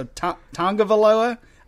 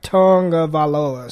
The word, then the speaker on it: Tagovailoa: For what it's worth, I do hear people pronounce it who sound like they know how to pronounce it, and I hear a little N at the beginning. So Tagovailoa, I don't know if that's... – Yeah. It's the way it was like spelled out phonetically or however that goes is tongue, like Tagovailoa.